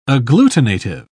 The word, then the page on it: agglutinative: British English